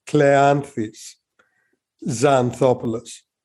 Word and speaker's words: Kleanthis Xanthopoulos: I'm torturing you with an ancient Greek name, so it's not very common at all. It's Kleanthis Xanthopoulos.